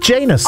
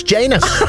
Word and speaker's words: Janus: It rhymes with anus. It's Janus.